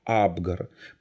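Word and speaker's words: Апгар: Абгар – это фамилия такая, именно с ударением на первой букве «А» – Абгар.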